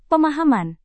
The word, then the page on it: pemahaman: Indonesian